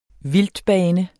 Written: vildtbane

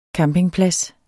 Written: campingplads

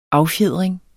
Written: affjedring

